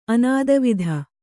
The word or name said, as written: ಅನಾದವಿಧ